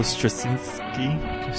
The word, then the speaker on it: Straczynski: By J. Michael Straczynski. – Said correctly, sir.